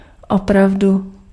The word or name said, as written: really